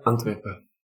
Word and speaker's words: Antwerpen: Antwerpen, I think, is the correct pronunciation. – Yeah, Antwerpen.